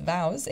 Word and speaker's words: Voz: In addition to his duties at Stanford, he is on the board of VOWS, which is actually spelled V-O-Z, but pronounced VOWS. It means voice in Spanish.